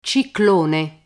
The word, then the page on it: ciclone: Italian